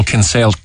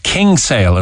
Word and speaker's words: Kinsale: Uh, a, a lot of a, a, lot of people call uh, in Cork call Kinsale King'sale as well. Put the King G into it. I think that's the old yeah. the old pronunciation from uh, from way back when. Um, All the Norries call it King'sale. King'sale, the, yeah. The Sorries call it the, the Sorries call it Kinsale.